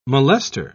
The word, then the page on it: molester: British English